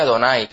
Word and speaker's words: Adonai: Y siempre que se escribe se pronuncia Adonai, que literalmente quiere decir Señor, mi Señor, quiere decir directamente.